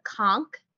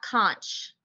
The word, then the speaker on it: conch: Good one was how many ways you can prepare their national dish called the conch, not conch. They'll make fun of you if you pronounce it that way.